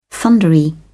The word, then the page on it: thundery: British English